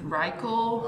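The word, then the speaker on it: Reichl: Also, I have to correction myself, is that I learned that I had been pronouncing her name wrong. It's Ruth Reichel, not Ruth Reichel.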